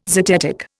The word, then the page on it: zetetic: Indian